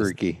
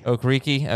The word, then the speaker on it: Okereke: Okereke. – Okereke.